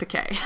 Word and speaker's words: Takei: Well, eventually in the first season, there's an episode with George Takei, and you learn that it's pronounced Takei.